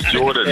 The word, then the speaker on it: Jordan: Is it Gordon? How do you say your name? – Good morning, Martin. It's Jordan.